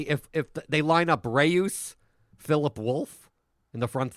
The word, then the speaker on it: Reus: It's Reus. I call it Reus. Reus.